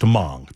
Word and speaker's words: Hmong: It's pronounced Hmong.